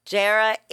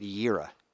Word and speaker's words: Jera: J-E-R-A? Jera? – I've always heard that pronounced Yera.